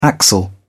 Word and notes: axle: British English

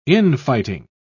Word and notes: in-fighting: British English

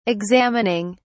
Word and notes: examining: British English

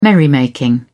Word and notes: merrymaking: British English

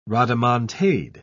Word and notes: rodomontade: British English

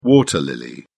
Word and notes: water lily: British English

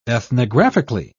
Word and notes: ethnographically: British English

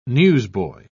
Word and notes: newsboy: British English

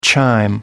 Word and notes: chime: American English

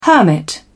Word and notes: hermit: British English

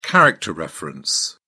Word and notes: character reference: British English

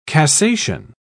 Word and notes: cassation: British English

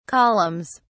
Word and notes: columns: British English

